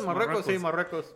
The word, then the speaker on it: Marruecos: Canadá contra Morocos. – Cabrón. Imagínate que estás viendo Canadá contra Marruecos. – Marruecos. – No mames. – Eso se dice así, güey.